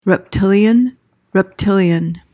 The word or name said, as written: reptilian